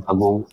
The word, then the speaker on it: Pagod: Also ich bin ja, das Restaurant in München heißt Pagod, das hast du schon richtig ausgesprochen. Mein lieber Jörg, das stimmt, mit 10 nach Deutschland in Pagod geboren und aufgewachsen.